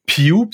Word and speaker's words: Pugh: Puis c'est euh, c'est l'année d'après que William Pugh, parce que tu, tu l'as prononcé Pug, mais j'ai vu. – Ouais, j'ai prononcé Pug, mais P-U-G-H, t'es, allé checker la prononciation exacte.